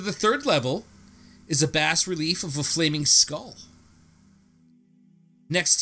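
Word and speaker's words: bas-relief: I believe it's a bas relief. Like the fish.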